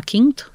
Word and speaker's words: KINTO: Então, essa empresa se chama Quinto, se escreve K-I-N-T-O.